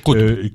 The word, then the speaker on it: Qutb: Alors ça, c'est Sayyid Qutb, j'ai du mal à prononcer. – Oui, Qutb.